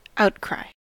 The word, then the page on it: outcry: American English